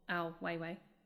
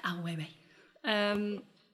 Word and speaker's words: Ai Weiwei: Do you the question there is, do you think that, for example, Ai Weiwei, if I've said that right. – You said it right. You said it beautifully. – Ai Weiwei.